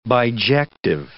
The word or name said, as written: bijective